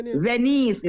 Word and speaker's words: Venies: Venice, V-E-N-I-E-S.